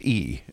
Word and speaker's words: Yi: why is it Y-I but pronounced E? – So thank you again.